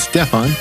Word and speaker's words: Stephan: Uh, he corrected me. I was mispronouncing his name earlier. It's Stephan.